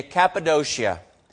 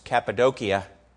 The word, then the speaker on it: Cappadocia: And we're going to spend some time where some of those early suffering Christians, in I guess the modern pronunciation is Cappadocia, where they hung out.